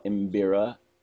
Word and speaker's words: Mbira: The instrument is called Mbira, and I'll spell it M-B-I-R-A. So you can say Mbira.